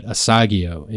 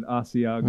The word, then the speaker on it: Asiago: But I've always pronounced Asiago bread Asagio, and she goes, first of all, that sounds nasty, and secondly, there's no way that's phonetically correct. How would you pronounce? Is it Asagio or Asiago? – I've always said Asiago.